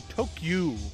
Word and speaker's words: Tokyu: It was actually owned by the I want to say this right, the Tokyu Corporation, the T-O-K-Y-U Corp.